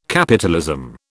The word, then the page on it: capitalism: British English